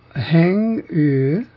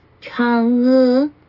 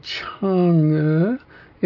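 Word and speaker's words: Chang'e: It is CH. I played it several times. – Chang E. – I either missed it or it wasn't playing it. I couldn't hear the CH. I heard just an H sound. – Chang E. – Chang E. – Chang E. – Chang E. It has to come up. The inflection is upwards on both syllables.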